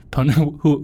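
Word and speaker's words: Ponemon: It Poneman, the E silent, but... – However you pronounce that fantastic institute, they have a state of a data breach report that they do every year. – Ponymon.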